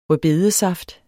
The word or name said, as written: beta